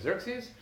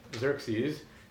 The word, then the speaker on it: Xerxes: So like X in Xerxes is the Greek Ch, right? So even the same spelling is actually an attempt to write Xerxes, which is not so far off from Ahasuerus, or at least closer to Ahasuerus.